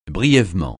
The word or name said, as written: brièvement